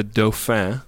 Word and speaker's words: Dauphin: Yeah, so the episode title is The Dauphin. – What does that even mean? – That is the French word for the prince who will take the throne when the king dies or abdicates. So the Dauphin is like the heir apparent.